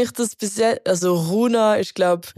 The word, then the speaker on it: Rounhaa: Entweder heisst er äh, Runa oder Rona oder Runa. – Ich glaube, man sagt Runa.